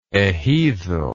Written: ejido

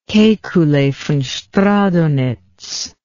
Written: kekule von stradonitz

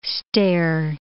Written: stare

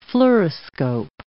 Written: fluoroscope

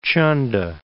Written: Chanda

